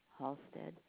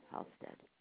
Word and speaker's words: Halstead: Jean, J-E-A-N, Halstead. Last name is spelled H, as in hat, A-L, S as in Sam, T as in Tom, E-D, as in David, Halstead.